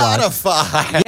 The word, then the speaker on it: Forgotify: Forgotify. – Yeah, so it rhymes with Spotify.